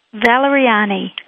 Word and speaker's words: Valeriani: Is that how you say it, Audrey? – Valeriani. – Valeriani. Sounds like an aria.